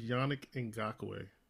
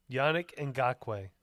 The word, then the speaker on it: Yannick Ngakoue: All right, so speaking with pronunciation, when you do your podcast, his name is Yannick Ngakoue. – Yannick Ngakoue.